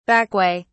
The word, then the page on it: backway: British English